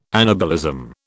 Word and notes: anabolism: British English

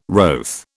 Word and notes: wroth: British English

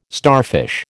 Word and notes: starfish: American English

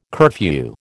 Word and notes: curfew: American English

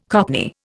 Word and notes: cockney: Indian

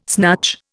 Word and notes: snatch: Indian

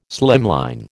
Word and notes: slimline: American English

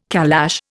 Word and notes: calash: Indian

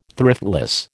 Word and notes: thriftless: American English

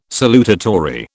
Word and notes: salutatory: British English

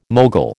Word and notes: mogul: American English

